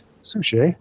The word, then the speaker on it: Suchet: Suchet.